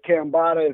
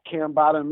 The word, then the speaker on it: Cambatta: Secondly, again, I go by the name of Cambotta. If you haven't heard it, now you know. – Mm-hmm. – You can find me at Cambotta Music. It's spelled C-A-M-B-A-T-T-A Music.